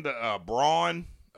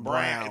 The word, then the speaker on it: Braun: How do you say his name? The Braun. For Denver? – Yeah, Brown. They call him Brown.